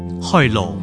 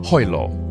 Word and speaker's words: 海螺: Uh, how do you say that? 海螺。 Hoilo. Hoilo.